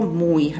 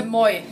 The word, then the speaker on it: Moi: Äh, moi. Moi. Genau. moi wie das äh, holländische Schön.